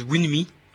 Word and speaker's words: Wunmi: un peu de musique, oui, avec les Masters at Work, accompagnés de la chanteuse Winmi, je sais pas comment on prononce ça, Winmi, W-U-N-M-I, voilà. Le morceau s'appelle Ekabo. Et voilà, c'était les Masters at Work accompagnés de la chanteuse Winmi, ou Winmi, c'est comme vous voulez.